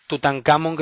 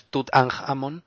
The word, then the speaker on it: Tutankhamón: Tutankamón, que si lo quieres pronunciar como debería es Tutankhamón, que suena a jamón, por eso no lo suelo decir yo, pero bueno, originalmente era Tutankatón.